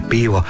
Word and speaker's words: pivo: Ono što bismo u kafiću naručili ima dugosilazni naglasak i izgovara se pivo, a lokalna dalmatinska inačica glagola pjevati izgovara će se ipak drukčije.